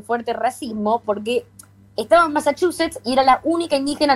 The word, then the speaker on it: Massachusetts: Por su familia adoptiva, descendientes del pueblo originario Mi'kmaq en Estados Unidos, quienes la cuidaron y criaron en esta palabra en la que más me cuesta pronunciar, Massachusetts. – 11 puntos, perfecto, Massachusetts.